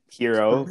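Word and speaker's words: Herro: Did you not see Tyler Harrow? I mean, Hero. Pronounce his name right first.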